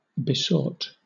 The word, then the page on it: besought: British English